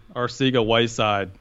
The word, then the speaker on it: Arcega-Whiteside: Arcega-Whiteside.